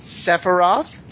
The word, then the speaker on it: Sephiroth: We have Matthew, or Sephiroth. Did I say that correctly? – You did say that correctly, yes. – Yay! Oleg, or Zver, Z-V-E-R in the game.